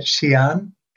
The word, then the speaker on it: Xi'an: And that's the Xi'an English branch. Did I say that right? – They say it Xi'an, X-I apostrophe A-N.